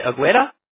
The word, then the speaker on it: Agueda: A-G-U-E-D-A. – E-D-A. Am I pronouncing this right? Is it Agueda? – Really close. I just say it Agueda as if it was all A's.